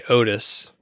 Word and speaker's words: Odys: I just kept saying ODYS, and then someone told me, you could just say Otis, which so that's shorter.